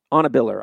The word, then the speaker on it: Anna Biller: Even with an entree of a friendship with the aforementioned Anna Beiler, or Anna Biller. Anna Biller, I'm sorry, I was pronouncing her name wrong.